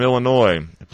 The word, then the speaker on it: Illinois: State model from Illinois, please don't pronounce the S.